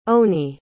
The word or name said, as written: onie